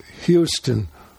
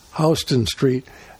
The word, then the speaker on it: Houston Street: Some people call it Houston, but in New York it's pronounced Houston Street.